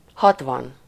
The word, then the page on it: soixante: France; /swa.sɑ̃t/